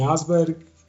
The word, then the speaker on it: Asberg: Então é Edwin Asberg, E-D-W-I-N, Asberg como, como se fala mesmo.